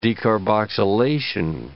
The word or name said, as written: decarboxylation